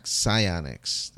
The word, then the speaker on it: Psyonix: Yeah, the game initially released in 2015, July, and the publisher is Psyonix. There we go. That's how you pronounce that word.